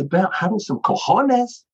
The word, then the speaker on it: cojones: Cojones, claro.